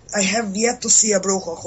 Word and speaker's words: brujo: But then, with brujos, no.